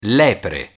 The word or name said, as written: lepre